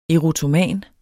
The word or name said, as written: erotoman